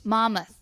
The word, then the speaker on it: Monmouth: Monmouth, yeah. The N is silent.